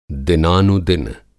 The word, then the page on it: दिनानुदिन: Hindi